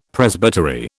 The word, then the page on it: Presbytery: British English